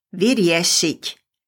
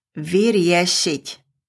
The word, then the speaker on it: vyriešiť: It is vyriešiť. Repeat, vyriešiť.